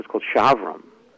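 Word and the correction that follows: Chaverim: Am I saying that right? Shavram? – Havarim? And this is near Lakewood? – And they're in Brooklyn. – Havarim. Havarim? – Havarim, thank you.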